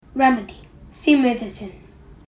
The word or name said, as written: remedy